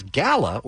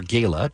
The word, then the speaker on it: Gala: apple, as Gala, or Gala, depending on how you pronounce it, takes the number 1 spot.